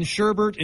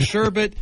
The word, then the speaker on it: sherbet: We let people start saying sherbert instead of sherbet, and all of a sudden we're all I've never heard sherbet ever in my life.